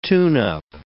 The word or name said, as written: tune-up